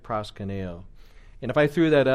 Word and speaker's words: proskuneo: I could spell it out for you in English letters: P R O S K U N E O. That's not how it's written in Greek, but then you guys could look at that, pronounce it proskuneo, and then I would say, which is translated worship or bow down.